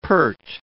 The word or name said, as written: pert